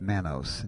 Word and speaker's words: D-mannose: It's a capital D, and then a dash, and then an M-A-N, just like man, and then N-O-S-E, like nose, but it's pronounced mannose.